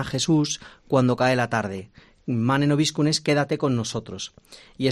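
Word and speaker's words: Mane nobiscum: Sed bienvenidos a este espacio y nosotros vamos a empezar preguntando, que seguramente nuestros oyentes pregunten que qué es esto de Manen Obiscun, si no lo pronuncio mal, si no, corrígeme. – Está bien pronunciado.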